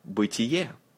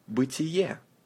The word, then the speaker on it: Бытие: First book, Бытие. Бытие. Say, тие. Бытие. Stress is on the last vowel. Бытие sounds like a word to beat in English.